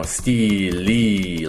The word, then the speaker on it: stele: So a stele is spelled like this, but it's pronounced more like, you know, stele, like this. Okay, because we say both E's, like long E's.